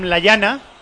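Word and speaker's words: Lallana: Sí, ha sido un golazo de Adam Layana, que dice el chico que se dice así, porque es de origen español.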